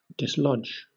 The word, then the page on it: dislodge: British English